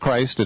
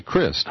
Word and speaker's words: Crist: Well, his term expired, and a guy named Crist, not Christ, it's spelled that way, but Charlie Crist, uh-huh.